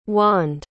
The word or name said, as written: wande